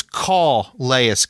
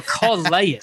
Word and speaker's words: Calais: But you keep saying Calais Campbell. We got to say it right now that he's a Raven. It's Calais Campbell. – There you go. Calais.